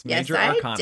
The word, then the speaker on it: Major Arcana: Did I say it right this time? – Yes, Major Arcana. Yes, I did. I want to say Arcadia every time.